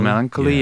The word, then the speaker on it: melancholy: like the melancholy melanch- melancholia how you say it melancholy, melancholy yeah.